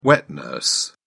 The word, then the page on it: wet-nurse: British English